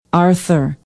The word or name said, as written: arthur